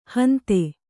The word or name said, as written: ಹಿಂದೂ